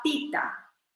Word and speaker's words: pitta: Se escribe B-A-T-A, -A, bata. ¿ok? Si hablamos del elemento del fuego, estamos hablando del dosha pita, P-I-T-T-A.